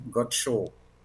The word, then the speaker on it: Godchaux: Sí, es un apellido francés, Godchaux sería, se pronunciaría así, Godchaux.